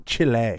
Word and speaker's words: Chile: Philippines, Serbia, Chile, Ukraine. – It's Chile. – Chile. – Yeah, say it right.